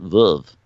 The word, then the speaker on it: Veuve Clicquot: here's Aunt Veuve, you know? It's Veuve, but she says Veuve like Veuve. Mm-hmm. She probably says Veuve like Vive. – Yeah. It sounds like Verve. I always pronounced it Verve.